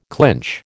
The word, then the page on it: clinch: American English